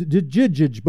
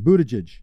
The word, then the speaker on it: Buttigieg: Yeah, it, it, it's a hard G, isn't it? – No, I promise. It's Buttigieg. – The first, the first G is a soft G. – Both of them. Both of them are soft G's. He oh, says it. Both soft G. Buttigieg.